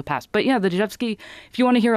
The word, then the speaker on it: Rzewski: It's spelled R-Z-E-W-S-K-I, but pronounced Dzhevsky.